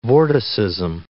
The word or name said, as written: vorticism